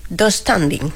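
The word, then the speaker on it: döstädning: Yo lo pronuncio... Dos standing. – Sí, así. Sí, tengo esa silla de Ikea, sí.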